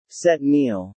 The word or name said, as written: set meal